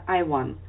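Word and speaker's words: Ivan: Его имя Иван, вот пишется Иван. Я спросила, а почему у тебя такое имя? Родители назвали, потому что им это имя очень понравилось. На английском звучит это Айван.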